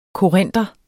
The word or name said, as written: korinter